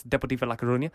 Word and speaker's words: Deportivo La Coruña: He then went to Deportivo La Coruña in España. Shout out to everyone who says that we can't pronounce things properly. That's Deportivo La Coruña.